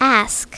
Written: ask

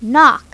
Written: knock

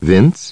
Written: Vince

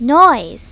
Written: noise

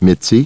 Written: Mitzi

